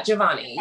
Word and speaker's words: Giovanni: Did I say his name right? – Yeah. Giovanni. Yeah.